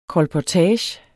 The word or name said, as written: kolportage